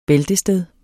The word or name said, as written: bæltested